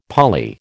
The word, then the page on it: poly: American English